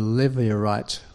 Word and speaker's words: Levirate: Now, this idea of the Levirate, I'm going to say it right, L-E-V-I-R-A-T-E, Levirate law, was one that particularly talked about a kinsman redeemer.